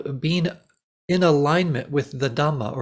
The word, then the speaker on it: dhamma: And dhamma is just the Pali pronunciation of the same word. Dharma is in Sanskrit.